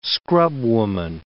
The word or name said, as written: scrubwoman